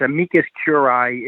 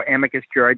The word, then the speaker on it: amicus curiae: It's amicus curiae is, or amicus curiae, depending on how you want to pronounce it.